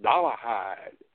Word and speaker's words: Dollarhide: and they still pronounce it Dollarhide. They don't pronounce the R. So it got added in spelling only.